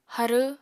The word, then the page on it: harw: apatani